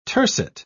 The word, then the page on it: tercet: British English